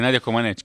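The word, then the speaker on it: Nadia Comăneci: משהו עליה, נדיה קומנץ', כן, כן, ככה מבטאים את שמה.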